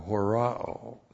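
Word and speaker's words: horao (ὁράω): And then we have the verb horao. Aeon H-O-R-A, long O, horao.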